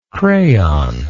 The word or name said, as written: crayonist